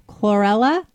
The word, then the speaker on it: chlorella: It's time for Healthy Steps with Dr. Ginger, and we're going to talk about chlorella.